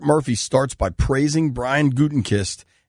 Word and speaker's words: Gutekunst: and then Paul Gutenkist. I do a very poor job of pronouncing his name. And let me clarify, his first name is Brian, not Paul. Brian Gutekunst.